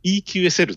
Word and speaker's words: EQSL: EQSL、 EQSL、 EQSL って言うんです。いや、 EQSL っていう発音してますね。